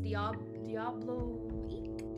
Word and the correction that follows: Diabolique: Can you Google how to say that word? Let's find out, shall we? Because God knows I don't know how to say it. Diablo. All right, let's try that again. On the 4th, I watched Diabolique.